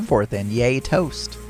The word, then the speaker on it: gjetost: And I looked up how to pronounce this, and I believe it's pronounced yay toast. But it's spelled G-J-E-T-O-S-T.